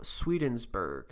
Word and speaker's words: Swedenborg: A Christian sect founded in the principles of the occultist Emanuel Swedenborg. I looked it up. That's how you pronounce it. It's just Sweden and then Berg.